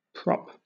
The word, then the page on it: prop: British English